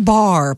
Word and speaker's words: Barre: B-A-R-R-E, but it's pronounced Bar.